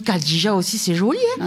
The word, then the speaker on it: Khadija: On dit Khadija. – C'est parce que K-H, ça fait Kha.